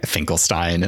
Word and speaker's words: Finkelstein: Stop is is he called Dr. Frank- Finkelstein, not Frankenstein? Finkelstein, yeah. Um, um, they only say his name a couple times, but yeah, she's like this kind of ragged.